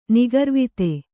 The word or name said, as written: ನಿಗರ್ವತೆ